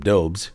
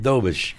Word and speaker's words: Dobusch: Now, a lot of people would say Jacob Dobes, because that's how it's spelled, right? – Dobes. – But it's Jakub Dobusch.